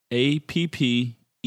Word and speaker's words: appellation: Two P's and two L's in that one. It's just a, that could be a spelling bee jointy. – Oh, how it's spelled? – APPELLATION. – Oh, all right. – So it's not like apple.